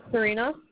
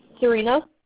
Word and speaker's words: Serena: Yeah, Serena. – I'm sorry, what's your name? – Serena. – Okay, can you spell that for me? – S-E-R-E-N-A. – S-E-R? – E.